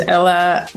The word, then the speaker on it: Ella: My name is Ella.